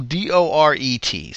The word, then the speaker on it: Doret: The cover to Rock and Roll Over, this one was painted by Michael Doray. I'm totally butchering his name. Apologies, Michael. It's Michael Doret.